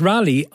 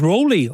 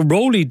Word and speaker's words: Raleigh: Thank you to the listener who says, well, what I pronounced is Rally, R-A-L-E-I-G-H. Apparently they pronounce it as Rowley over there. So Rowley, Durham.